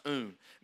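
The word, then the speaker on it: oun: It's pronounced un.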